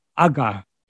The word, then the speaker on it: agha: In, if it's transliterated into English, it's A G H A, that Sanskrit word. That is uh, the aspirated G, a G, um, which means sin, impurity, evil, pain, or suffering.